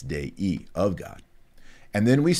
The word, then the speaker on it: Dei: That I end, and you don't say Dei, you say Dei. There are two syllables there, Dei, not Dei, Dei, of God.